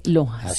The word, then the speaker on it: LOHAS: Café Lojas se escribe L-O-H-A-S. – Café Lojas. Así es.